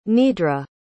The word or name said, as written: nidra